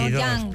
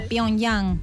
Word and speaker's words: Pyongyang: Pyongyang. – Ahí está, pero ahí miren, está, miren, 22. Además, 22, Pyongyang, qué bien pronunciado, Pyongyang.